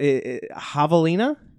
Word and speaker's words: javelina: But javelina? – Yeah, that is correct.